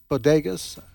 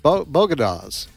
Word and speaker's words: bodegas: And by the way, how did she pronounce bodegas? Bogotas in the Bronx.